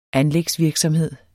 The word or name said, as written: anlægsvirksomhed